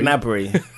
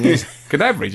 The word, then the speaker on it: Gnabry: Gnabry. – Well, shouldn't it be a silent G? – Can we just call him Serge? They didn't go with a silent G on Sky. They went with Gnabry.